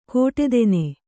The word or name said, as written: ಕೋಟೆತೆನೆ